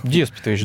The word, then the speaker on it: Деспотович: Деспотович, да. Деспотович. Там двойное ударение, да.